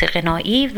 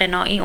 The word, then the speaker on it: غنایی: ببینید بچه ها دقت بکنید به تلفظ من عزیزان، غنا درسته، برخی دوستان میگن ادبیات غنایی، ما غنا و غنی و اینها هم داریم، ولی چیزی که در ادبیات مطرحه ادبیات غنایی هست نه غنایی، اون چیزی که امروز میخوایم در موردش صحبت بکنیم.